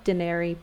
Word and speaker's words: Dyneri: And so we came up with this hybrid word of, based off dinero, dineri, but it's spelled D-Y-N-E-R-I, because, you know, you have to be a cool fintech company and add some crazy vowels in there.